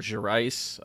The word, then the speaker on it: Gerais: He was an enlisted soldier with the military police in the state of Minas Gerais, or Gerais. I've heard it pronounced both ways, but he was a resident of Belo Horizonte, where he lived with his entire family.